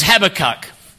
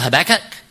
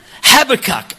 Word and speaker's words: Habakkuk: His name is Habakkuk. And if you're in America, he'd be called Habakkuk. It's okay for them to get wrong. They always put the emphasis on the wrong syllable, and that's not my issue.